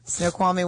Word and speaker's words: Snoqualmie: I'm going to be in, I don't know how to say it, Snoqualmie? – Yep. – Is that it? Did I say it right? – Yep. – Snoqualmie, Washington, November 22nd.